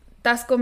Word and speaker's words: Taxco: You Google, it's spelled Taxco, you guys. – Like taxes, then co. – Yeah, Taxco, Mexico.